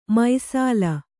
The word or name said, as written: ಮೈಸಾಲ